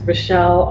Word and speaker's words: Richael: I'm pretty easy to find on the internet at Richelle, R-I-C-H-A-E-L, spelled like Michael, Faithful.